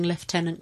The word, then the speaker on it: lieutenant: Thank you for pronouncing lieutenant correctly.